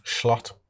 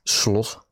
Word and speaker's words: Slot: By the way, if you know, you pronounce his name, Slot. – I got told that it is basically Slut, which is excellent.